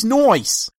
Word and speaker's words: Noyce: Is that how you say his name? Noyce? – Yeah. It's Noyce.